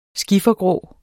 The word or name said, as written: skiffergrå